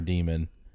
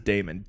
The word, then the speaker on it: daemon: Yeah, that stupid Mailer Demon. – It's not a demon. It says Damon. D-A-E-M-O-N.